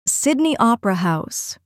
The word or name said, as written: Sydney Opera House